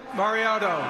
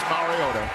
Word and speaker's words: Mariota: 9 years ago, Roger Goodell, in the first round, butchered the name of Marcus Mariota. – With the second pick in the 2015 NFL Draft, the Tennessee Titans select Marcus Mariota, quarterback, Oregon. – And it is Marcus Mariota in Hawaii.